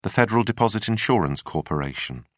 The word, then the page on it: federal deposit insurance corporation: British English